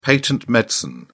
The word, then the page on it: patent medicine: British English